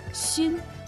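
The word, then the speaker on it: 薪: «Син» произносится в первом тоне, значит «хворост дрова» шан во втором доме.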